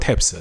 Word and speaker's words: WTAPS: 啊，稍微普及一下，Double Taps 实际上是 W 打头，W T A P S，但搿只牌子 W 是勿发音个，侬可以叫伊 Double Taps，侬也可以叫伊直接叫 Taps。